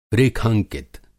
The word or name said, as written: रेखांकित